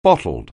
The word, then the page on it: bottled: anglais